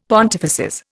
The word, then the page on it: pontifices: Indian